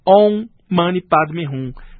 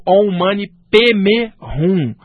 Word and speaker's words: Om Mani Padme Hum: No Tibete, às vezes a pronúncia pode mudar um pouquinho, tipo Om Mani Padme Hum, ou então Om Mani Padme Hum. Já na China, os chineses, como não sabiam falar o sânscrito, adaptaram às vezes da seguinte maneira, Om Mani Padme Hum, né? Ou Om Mani Padme Hum. Então a pronúncia pode variar, se é chinesa, tibetana, mas o original vem do sânscrito, mas esse mantra é mais aplicado no budismo tibetano.